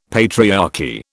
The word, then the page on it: patriarchy: British English